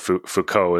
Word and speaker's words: Foucault: Oh, and I realized I was saying his name wrong. It was, as you said, it Foucault is the proper pronunciation.